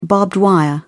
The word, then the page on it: barbed wire: British English